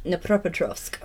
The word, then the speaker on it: Dnipropetrovsk: How do you say it again? – Dnipropetrovsk. – Dnipropetrovsk. – But look how it's spelled. It's a silent D.